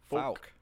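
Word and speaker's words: Fouke: It's F-O-U-K-E. Fook. – Falk. – The Falk. Stop coming in here with proper pronunciation.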